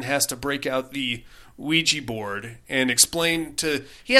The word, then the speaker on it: Ouija: No, and it's pronounced Ouija. – Ouija. – And it comes from the German and French. – Oui and ya.